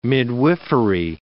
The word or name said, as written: midwifery